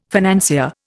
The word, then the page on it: financier: Indian